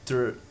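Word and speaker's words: DTRH: Dirt. – Dirt.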